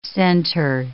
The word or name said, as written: centre